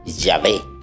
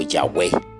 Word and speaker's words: Yahweh: Según los expertos de la Biblia, cuando el israelita encontraba en el libro estas cuatro letras, pronunciaba el nombre de Dios diciendo Yahvé o Yahweh, que en la Biblia se traduce como el Señor.